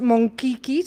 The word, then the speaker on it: Monchichi: Se llaman los Monkiki, se escribe Monchichi, ¿te suena? – Monkiki era mi profesor de geografía.